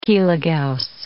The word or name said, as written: kilogauss